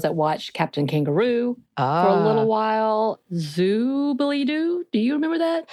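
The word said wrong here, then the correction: Zoobilee Zoo: Oh, no. That's not how you say it. It's Zoobly-Zoo.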